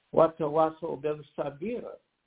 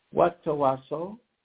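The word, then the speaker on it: watawasso bil sabira (وتواصوا بالصبر): Then patience in the Quranic Arabic, it is pronounced watawasso bil sabira. Don't try to take on the burden by yourself. Again, it is watawaso.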